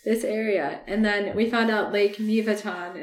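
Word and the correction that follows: Mývatn: is actually pronounced Miva because in Icelandic, M Y V A T N, the T N just guides which A sound you put.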